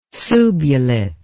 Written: subulate

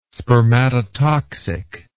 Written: spermatotoxic

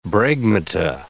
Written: bregmata

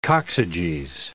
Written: coccyges

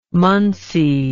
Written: munsey

